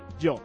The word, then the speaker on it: Jo: Literalmente, se escribe J-O, jo, pero bien pronunciado se dice yo.